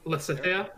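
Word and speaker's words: Lysithea: Lysithia? – Lysithia? – Yes, thank you.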